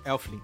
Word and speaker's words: Elflin: Mas enfim, o, o, o, o Elfine... Elflin. É isso? Falei errado.